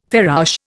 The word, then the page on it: farouche: Indian